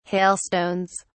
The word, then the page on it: hailstones: British English